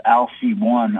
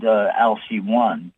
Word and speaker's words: Alcyone: The seven major stars, and of course there's planets revolving around the stars, like the center one is called Alcy One. I mean, that's how I pronounce it, A-L-C-Y O-N-E.